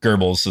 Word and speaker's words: Goebbels: So basically Joseph Goebbels, is it Goebbels? – Goebbels is how you pronounce it. Goebbels.